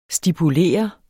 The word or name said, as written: stipulere